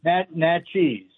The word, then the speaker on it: Natchez: Is it nachos or is it notches? How do you pronounce that? – Natchez. – Natchez. Okay, want to make sure I'm driving the whole time. I'm like, for sure I'm going to butcher this. So thank you for clarifying that for me.